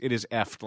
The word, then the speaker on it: eft: And by the way, it is effed like heft. – It is effed like heft. – I always see it in crossword puzzles. I never know how to pronounce it. EFT. It is an EFT.